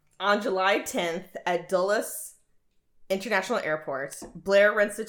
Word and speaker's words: Dulles: On July 10th, at Dulles International Airport, is that how you pronounce it? – Dulles. – Okay. – It's Dulles.